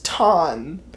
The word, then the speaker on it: Tonn: So, when I saw your name, Toad, it is spelled T-O-D-D, right? – That's Toad. The correct spelling. – Okay. So, my name is Ton, spelled right. T-O-N-N. Mm-hmm.